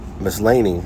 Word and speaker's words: Maslany: Tatiana Maslany, Miss Laney, that's how you say it, I'm sorry.